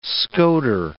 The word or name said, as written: scoter